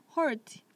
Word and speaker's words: heart: 그 heart 생각하면 그냥 e 빼고 hart. – hart. – 네 그렇게 발음하면 heart.